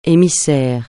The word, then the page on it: émissaire: français